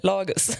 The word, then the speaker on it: Lagus: Jag berättade att det var finskt eh, och då tyckte du att det bör uttalas Lagos istället för Lagus. – Ja, men det stavas ju bara med ett A.